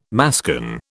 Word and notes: mascon: British English

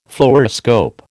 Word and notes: Fluoroscope: American English

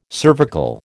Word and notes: cervical: American English

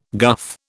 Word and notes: guff: British English

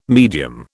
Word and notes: medium: British English

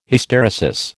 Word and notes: Hysteresis: American English

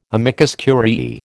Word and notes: amicus curiae: American English

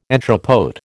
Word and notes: entrepôt: American English

